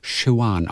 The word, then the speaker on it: Siobhan: Also Siob Hahn geschrieben. – S-I-O-B-H-A-N. – Showan ausgesprochen. Irischer Name, glaube ich.